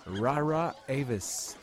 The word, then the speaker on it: Rara Avis: Bon, j'ai eu le temps de checker pour la prononciation de Rara Avis et selon Google, ça se dit Rara Avis. Fait que je pense que je suis pas trop loin.